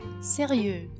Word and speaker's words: sérieux: O x no masculino não é pronunciado, sérieux, e o se no feminino tem o som do z.